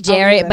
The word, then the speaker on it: Jarrett: Name is Jarrett, but it's spelled like Barrett with a J.